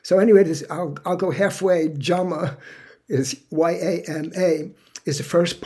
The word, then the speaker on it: yama: Now, one thing about the pronunciation of that word, I'm going to have to let you know this now, but when in Sanskrit, when a Y is in the beginning of a sentence or a word, it's pronounced like a J. So yoga is really yoga. And yama, which is the first part of this, is really jama.